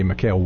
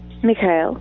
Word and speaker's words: Mikhail: And it's so funny, Mikhail wanted to go to, that's not how you pronounce it. How do you pronounce it? – Mikhail.